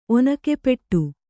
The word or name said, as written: ಒನಕೆಪೆಟ್ಟು